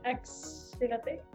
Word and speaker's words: axolote: Achelote. Axelote. – Ajá, ajá, Axelote.